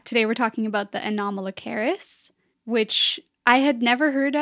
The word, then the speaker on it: Anomalocaris: It's Anomalocaris. – Anomalocaris. – Like it's very phonetic. Yeah. – Like anomaly. – Yeah, like an anomaly.